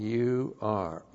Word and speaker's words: Ur: Ion, long U, R, Ur.